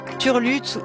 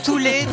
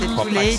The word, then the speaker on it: TUL8TE: Alors, l'artiste qu'on écoute actuellement, ça s'écrit T-U-L-8-T-E, donc Turlut ou Toulate, plutôt, comme propose Antonin Barre. Je crois qu'Antonin Barre a raison. – C'est Toulate.